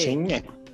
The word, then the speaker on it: Shinhe: Pronuncia pra galera. Xinhe. – Não. É, soletra. Soletrando. – Soletrando. – S-H-I-N-H-E. – Xinhe. Xinhe. – Xinhe. – Boa.